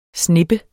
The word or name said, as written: snippe